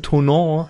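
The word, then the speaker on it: Thonon: Und ähm, als dritte Partie auf dem Spielplan steht dann Anfang Mai EFL Heimspiel gegen die, wie ich mir von äh, vom Centre Français habe sagen lassen, Tonon, werden die guten ausgesprochen, Tonon Black Panthers.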